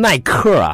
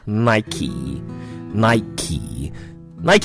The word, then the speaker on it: Nike: 对，里面还要注意你出现的这个 Nike 这个单词，千万不要读成耐克啊，它不读克，它是 Nike，Nike，Nike 其实就是在雅典里面是胜利女神的意思。